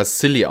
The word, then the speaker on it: Scilly: C-I-L-L. – Ah, Skilly. Achso. Mhm. Mhm. Eigentlich werden sogar silly ausgesprochen, deswegen sind die mhm. Silly Islands, was immer so ein bisschen ein Wortwitz im Englischen ist.